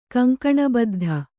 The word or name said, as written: ಕಂಕಣಬದ್ಧೆ